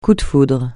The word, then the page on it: coup de foudre: French